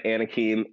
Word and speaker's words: Anakim: So I go by Anakim. It is spelled A-N-A-K-I-M.